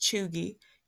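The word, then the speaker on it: cheugy: So Chugi, pronounced Chugi, can be used globally or broadly to describe someone who is out of date or trying too hard.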